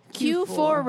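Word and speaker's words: Kue 4: Is it K4? – Q4 radio. – But it's spelled like K4. It's K-U-E 4.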